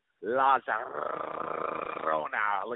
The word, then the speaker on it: La Llorona: If you're going to call cash grab, I think La Llorona is cash grab. – You didn't say it right. And all the fans got to miss out on my excellent La Llorona.